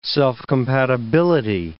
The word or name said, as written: self-compatibility